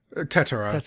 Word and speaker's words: Tetra: How are you pronouncing that? – Tetero. That's what they say in the English version.